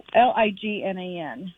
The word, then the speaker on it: lignan: So we started him on melatonin and I'm not sure if I'm pronouncing it correctly, but lignin, the flaxseed. – Okay. Flaxseed? – Yeah. It's called LIGNAN.